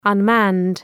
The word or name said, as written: unmanned